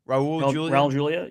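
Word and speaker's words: Raúl Juliá: Is it Raul Julia? – Raul Julia, yeah.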